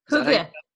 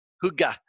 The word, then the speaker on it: Hygge: Hygge? – Hygge. – Hygge. – H-Y-G-G-E.